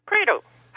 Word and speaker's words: Credo: Credo. – How do you spell that? – Like credo in unum deum. C-R-E-D-O.